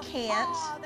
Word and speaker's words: can't: The word can't rhymes with paint. – Paint. Can't. Can't. – I can't. You don't say the T at the end so hard. Okay. You sort of swallow that.